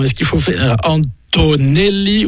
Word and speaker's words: Antonelli: Antonelli. Un peu à l'italienne, en fait, finalement, hein, comme on prononcera en italien. Antonelli.